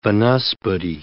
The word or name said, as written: vanaspati